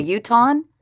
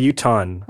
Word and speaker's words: Utahn: They, a Utahn. A Utahn. – Oh, so two syllables.